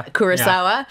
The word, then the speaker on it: Kurosawa: Kurosawa.